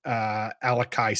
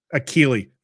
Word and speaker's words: Akili: I don't have anyone in the top five who's a third quarterback picked on here outside of Alakai Smith. Woof. Okay. – Akili.